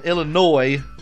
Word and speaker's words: Illinois: If the S is silent in Illinois, like y'all say, then why even put the S on the end of that motherfucker anyways? – Exactly.